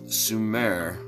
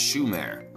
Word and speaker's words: Sumer: Sumer is actually pronounced Shumer.